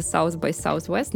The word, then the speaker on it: South by Southwest: Но еще я вспомнила любопытную штуку, я была на, я его называю по-русски uh, э, SXSW, но он называется South by Southwest, но это практически невозможно можно просто нормально произносить.